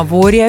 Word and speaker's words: воре: На воре или на воре, опять же, вопрос. Можно ставить ударение и так, и так.